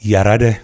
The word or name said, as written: Yarade